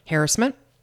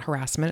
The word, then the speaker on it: harassment: She pronounces harassment correctly. – Most people do not. – Harassment. It's not harassment. It's pronounced harassment. I really appreciated that. Did you not know that? The word is not harassment. It's harassment. That's how you pronounce it.